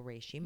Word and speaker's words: reishi: But I remember when I left here first, you gave me that beautiful reishi mushroom. – Mm-hmm. – Am I pronouncing it right? – You absolutely are.